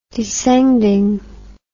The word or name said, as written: descendingly